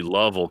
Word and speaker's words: Louisville: It's kind of like if you go into Louisville, Kentucky, it's actually Lovell, Kentucky.